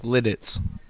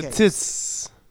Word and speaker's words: Lititz: How do you pronounce it? L-I-T-I-T-Z? – Lidditz. – Okay. Lidditz.